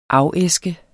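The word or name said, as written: AFÆSKES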